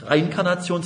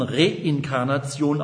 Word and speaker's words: Reinkarnation: Es wird also nicht Reinkarnation, sondern Reinkarnation ausgesprochen, aus dem Lateinischen reincarnem,